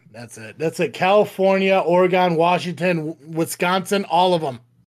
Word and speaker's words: Oregon: Oregon. Like you play an organ. Like your kidney is an organ. It is pronounced organ.